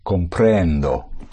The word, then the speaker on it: comprendo: I understand would be comprendo. – Comprendo. Push down, comprend, and you surface on O.